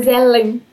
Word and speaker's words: Grazielen: Grazielen. É tem que ter um Ellen no final.